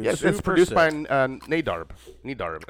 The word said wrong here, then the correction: Nedarb: No, Nedarb. – Nadarb, sorry. – Nedarb. It's actually Brandon Nedarb. Backwards.